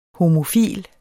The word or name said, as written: homofil